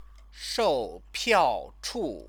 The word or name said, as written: shoupiaochu